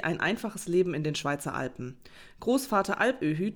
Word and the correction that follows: Alpöhi: tut. Öhü? Öhü. Großvater Alpöhi.